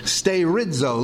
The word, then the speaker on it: sterizo: It is the verb sterizo. Looks like this: sterizo. Sterizo. Long E, pronounced A.